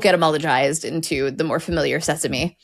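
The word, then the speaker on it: sésame: You can scotch that quite easily by pointing out that sesame in French is pronounced sesame.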